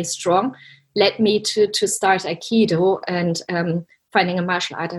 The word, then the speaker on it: Aikido: So Aikido is a Japanese word. It's a Japanese martial art, and it consists out of 3 syllables, I, Ki, and Do.